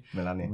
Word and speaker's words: Melania: Melania.